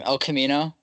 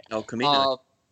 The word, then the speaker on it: El Camino: El Camino? No, I said that right. – El Camino.